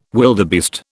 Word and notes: wildebeest: British English

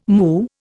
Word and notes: moo: Indian

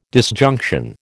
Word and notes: disjunction: American English

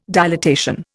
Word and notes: dilatation: Indian

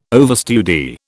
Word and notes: overstudy: British English

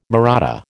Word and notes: Mahratta: American English

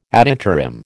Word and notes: ad interim: American English

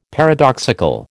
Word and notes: paradoxical: American English

